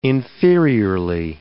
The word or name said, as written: inferiorly